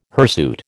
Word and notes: hirsute: American English

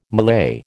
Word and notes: Malay: American English

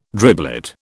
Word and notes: driblet: British English

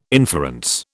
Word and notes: inference: British English

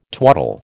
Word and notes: twaddle: American English

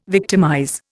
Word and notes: victimize: Indian

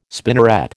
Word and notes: spinneret: American English

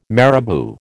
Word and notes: marabou: American English

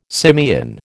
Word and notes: simian: American English

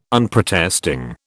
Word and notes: Unprotesting: British English

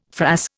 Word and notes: frass: Indian

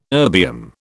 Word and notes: erbium: British English

